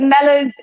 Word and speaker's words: Mellors: Yes, because Mellors, it rhymes with Smellors, and then Coco.